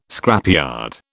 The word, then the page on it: scrapyard: British English